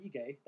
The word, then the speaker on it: Ige: Dan Ige, that's how you say it, by the way. Uh, Ige. No, it's Ige.